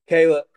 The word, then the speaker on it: Kallup: So not Kallup, Kallup. – Kallup, yep.